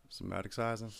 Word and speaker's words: somaticizing: S O M A T I C I Z I N G. – Somaticizing.